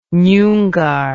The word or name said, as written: nyungar